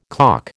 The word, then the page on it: calk: American English